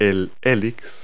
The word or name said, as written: helix